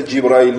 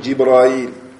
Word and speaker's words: Джибраиль: То есть и также читается Джибраиль. Можно говорить Джибриль, можно говорить Джибраиль.